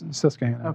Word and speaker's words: Susquehanna: Is it Susquehanna? – Yes, Susquehanna.